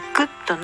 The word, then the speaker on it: Good: Good の D は聞こえませんが、言わないわけではないです。